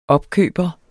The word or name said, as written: opkøber